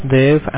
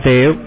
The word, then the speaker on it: Dev: My name is Dev, and I'm calling from the provider's office. – Can you repeat your name? I can barely understand, because your Yes, my name is Dev, spelled like D as a Delta, E as a Echo, V as a Victor.